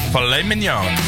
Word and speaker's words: filet mignon: Because it's French, so filet mignon.